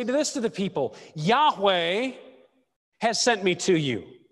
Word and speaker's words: Yahweh: But now, so the parallel is between the verb I am and the name Yahweh. Yahweh is how it would have been pronounced in Moses' day.